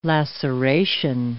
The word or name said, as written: laceration